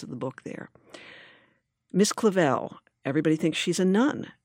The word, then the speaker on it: Clavel: But Miss Clavel, which rhymes with gazelle, mm-hmm.